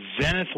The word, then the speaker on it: Zenith: Yesterday, guys, you guys were talking about Aaron and Zenith watches, and that's what it's actually pronounced, not Zenith like the old TV.